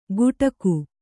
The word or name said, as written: ಗುಡಾಕು